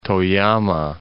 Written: Toyama

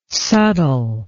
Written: subtile

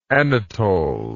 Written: anatol

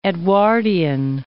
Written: Edwardean

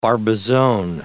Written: Barbizon

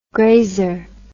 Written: grazer

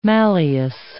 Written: malleus

